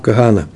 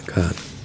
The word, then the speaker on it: Кагана: Надо говорить Кагана, но потому что ударение не может быть под, э, под геем, там потах со шва, или Кагана, или Кагана. Но так вот говорят в ашкенадских ешивах Кагана. Но ну, сегодня будем говорить Кагана, Кагана.